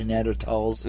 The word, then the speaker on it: Neanderthals: I like how you pronounce it properly, Neanderthals and mammoths at the same time frame? – Oh, absolutely.